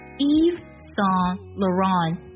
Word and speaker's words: Yves Saint Laurent: 这个牌子的全称呢，它读作 Yves Saint Laurent Yves Saint Laurent。